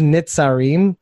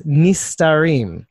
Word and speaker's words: Nistarim: What he says is Nitzarim, but he pronounced it wrong. – Oh, aha! No, that's so disappointing. – It's the 36 Nistarim. It's a different word completely, which is weird because Nistar means to hide, like hidden.